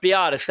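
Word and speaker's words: Biadasz: maybe Tyler Bedash kind of coming off it a little earlier. How you say his name. Tyler Bedash coming off the market a little earlier. – Biotis, that's it.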